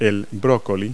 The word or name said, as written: broccoli